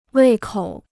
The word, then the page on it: 胃口: wèi kǒu